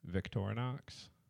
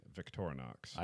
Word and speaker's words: Victorinox: Yeah, Victorinox. – I didn't know that. – It's loosely based on French. There's also that's really old French when they would pronounce the X.